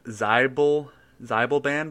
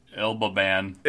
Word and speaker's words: Xibalban: And the item I'm bringing in, I don't know how to pronounce either, but I'm assuming it's the Zybelband blade. – That's a good guess. That's probably the best guess, unless that X is like silent. It's like the Ilbaban. – It's just for everyone at home that is playing along. It is spelled XIBALBAN blade. So however you want that to be pronounced, sure, it's a fantasy world and all this is made up. You're correct, that's how you pronounce it.